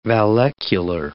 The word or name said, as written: vallecular